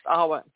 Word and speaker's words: Awen: It's actually spelled A-W-E-N, but it's pronounced Arwen.